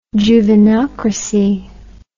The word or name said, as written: juvenocracy